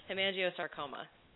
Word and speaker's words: hemangiosarcoma: How do you say it? – Hemangiosarcoma. – So not hemangiosarcoma, like I was thinking, you know, He-Man.